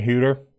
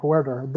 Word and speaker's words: Huerter: And then you've got – I'm going to pronounce his name right. Herder. – Kevin Huter. – Who Huter. – Has just been Huerter. That's the R that just throws me off.